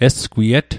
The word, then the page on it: esgwiet: Mi'gmaq